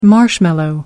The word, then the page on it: marshmallow: American English